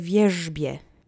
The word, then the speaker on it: wierzbie: Now you're ready to say wierzbie. Repeat after me three times. Accent is on the first syllable. Wierzbie wierzbie, wierzbie.